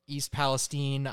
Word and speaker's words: East Palestine: Tragically, there was a chemical spill in East Palestine. Is that how it's pronounced? – I believe so. – East Palestine.